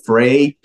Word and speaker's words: FRAIS: FRAIS Church. – Yep. You said it right.